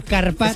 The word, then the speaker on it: carpaccio: Que te has perdido una letra. Carpaccio.